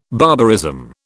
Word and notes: barbarism: British English

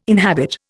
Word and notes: inhabit: Indian

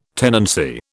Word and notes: tenancy: British English

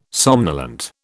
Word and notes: somnolent: British English

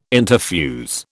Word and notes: interfuse: British English

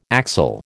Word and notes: axel: American English

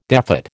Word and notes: defat: American English

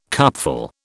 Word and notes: cupful: British English

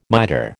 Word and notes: mitre: American English

